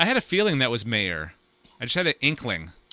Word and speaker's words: Maher: I guess his name is pronounced Mayer.